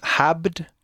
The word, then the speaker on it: habt: No, con B. Pero bueno, la pronunciamos un poco más fuerte. – Habt ihr. – Pero, ¿Se pronuncia con P o B? – Con, con B.